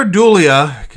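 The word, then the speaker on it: Dullea: It stars Olivia Hussey, Margot Kidder, John Saxon, Keir Dula. Dulia? I can't pronounce his last name. – Keir Dulia.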